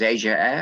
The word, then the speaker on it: Ayer: Ayer, Freddie Ayer, A-Y-E-R, that is, in case you don't know my pronunciation.